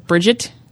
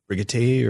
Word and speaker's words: Brigitte: Oh, Bridget? – It's Brigitte or something like that. It's not right. That's Brigitte. Not right. Brigitte. Yeah. – It's like Brigitte.